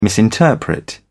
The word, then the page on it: misinterpret: British English